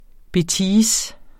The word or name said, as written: BETISE